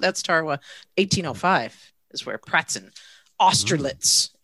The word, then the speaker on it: Tarawa: Tarawa.